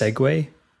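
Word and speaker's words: Segway: It's pronounced Sedgway.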